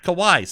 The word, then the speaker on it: Kawhi: Kawhi, sorry.